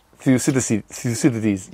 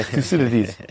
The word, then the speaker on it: Thucydides: Thucydides. How does he say his name? – Thucydides. Yeah, that one, yeah. – Yeah, Thucydides.